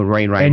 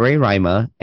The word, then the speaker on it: Roraima: No, Roraima, and you just pronounced it badly. – No, Mount Roraima, and it's in Venezuela, Brazil, and it's at the junction of Venezuela, Brazil, and I cannot pronounce his name.